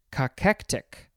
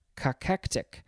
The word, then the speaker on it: cachectic: It is cachectic, cachectic, C-A-C-H-E-C, what did I say? C-A-C-H-E-C-T-I-C, cachectic.